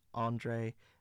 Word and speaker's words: Andra: Comes off his album Andra, pronounced more like Andre, which of course is Reggie's middle name.